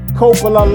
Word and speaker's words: Coppola: It's Coppola, but Coppola. That's what people usually say. – long O. All right, the Coppola gives me more of a "hey, forget about it" vibe, but it's all good. Coppola, long O.